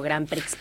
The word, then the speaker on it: Grand Prix: que conocemos como Grand Prix, pero la X no se pronuncia en francés.